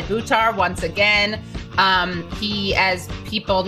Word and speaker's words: Buttar: And his name again is Shahid Buttar, and it's pronounced like this, guys. Shahid, so the accent's on the and Buttar, the accent's on the ooh.